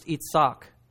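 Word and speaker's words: Itzak (יצחק): It's pronounced Itzak in Hebrew, almost the same word, and it means laughter, jest.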